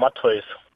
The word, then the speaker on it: Matthäus: Habe ich das jetzt richtig ausgesprochen? – Ja, richtig. – Sehr gut. – Einer der Seltenen. – Was? Einer der Seltenen, die das richtig aussprechen? Wie spricht man es denn sonst aus? – Naja, also die meisten sagen Matthäus. – Matthäus. – Wie kommt man denn darauf? Er hat gesagt, dass jemand sagt Matthews oder irgendwie so.